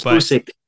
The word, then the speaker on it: Usyk: It's Usyk. – Usyk.